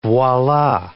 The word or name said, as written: voila